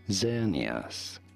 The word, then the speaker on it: Xerneas: This is to be said as Xerneas. Xerneas, not Xerneas. – Not Xerneas. – Z or Z sound. At the beginning, Xerneas, Pokemon, Xerneas, and now you know.